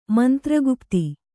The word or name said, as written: ಮಂತ್ರಗುಪ್ತಿ